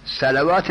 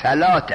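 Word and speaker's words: صلاة: صلوات نیست، صلات است. اون واوش تلفظ نمیشد، اقیم الصلات یا اقیم الصلوات، صلات. لکن چون واو را دیدن خیال کردن که این واو تلفظ میشد.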